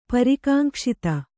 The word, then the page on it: ಪರಿಕಾಂಕ್ಷಿತ: parikāŋkṣita